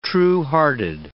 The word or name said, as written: truehearted